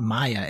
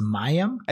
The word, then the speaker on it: Mayim: It's not Maya. It's Mayim.